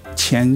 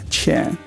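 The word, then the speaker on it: Tsien: Chen, his Chinese name will be pronounced Chen Shushen. So Shushen will be his first name, and his last name is spelled when he was in the U.S., T-S-I-E-N, Chen.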